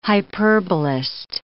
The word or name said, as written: hyperbolist